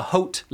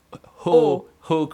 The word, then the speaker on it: haute: and the highest level are the haute level, in other words the high in French, is it haute, like haute cuisine, but it's haute, it's pronounced.